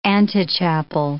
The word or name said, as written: antechapel